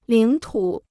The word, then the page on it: 领土: lǐng tǔ